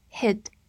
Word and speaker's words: hid: 注意，这里用到了 hide 的过去式，叫做 hid，去掉末尾的 e，同时发音变成 hid。